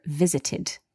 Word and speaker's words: visited: The ed here is pronounced id, visited.